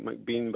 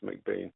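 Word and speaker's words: MacBain: it looks like McBean, but it's pronounced McBain.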